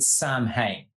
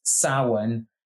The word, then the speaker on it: Samhain: It's not called Samhain. There is no character called Samhain. Samhain does not exist. It is Samhain. It is pronounced Samhain. It's spelt Samhain, but it's a different language.